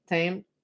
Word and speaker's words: Thames: So the Prospect of Whitby was right on the River Thames. Thames. – Silent H.